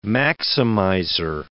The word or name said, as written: maximizer